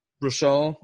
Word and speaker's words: Baruchel: What is your number nine that is Random Acts of Violence with an 8.5 by Jay Baruchel. – Dude. Rashal. – Rashal.